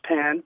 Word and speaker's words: pain: In French, their word for bread is pan, which is pronounced pan, but it's spelled P-A-I-N, which to us is pain.